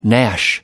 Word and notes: gnash: British English

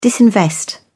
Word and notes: disinvest: British English

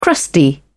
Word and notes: crusty: British English

